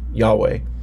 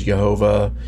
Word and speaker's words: Yahweh: or we would pronounce it as Yahweh. Some pronounce it as Jehovah.